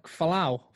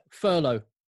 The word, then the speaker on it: furlough: It almost sounds like falow, the flowing of players. – How do you say it? – Furlough. – Furlough.